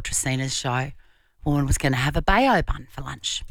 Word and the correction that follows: bao: As in like a bow? – Bow bun.